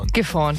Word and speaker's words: Gifhorn: Habe ich es richtig ausgesprochen? Gifhorn, ja.